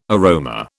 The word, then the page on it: aroma: British English